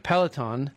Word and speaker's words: Peloton: Peloton,